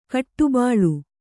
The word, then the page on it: ಕಟ್ಟುಬಾಳು: kaṭṭubāḷu